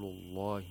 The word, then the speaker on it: الله: فقال لهم رسول الله ناقة الله وسقياها ناقة الله. The lamb of Allah is pronounced with تغليل, with thickness, because it's preceded by a letter with a فتحة.